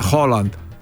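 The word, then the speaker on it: Haaland: Λοιπόν, πάμε τώρα να δούμε τα δικά μας, αφού πούμε δύο λόγια για τον Χάλαντ, ο οποίος έγινε γνωστός σήμερα γιατί στη φανέλα του, όπως ο Σταυρούλιας, λοιπόν, έτσι και ο Χάλαντ, ο οποίος προφέρεται Χόλαντ, να το ξέρετε αυτό, στη φανέλα του δεν γράφει μόνο Χάλαντ, γράφει Μπρουτ Χάλαντ.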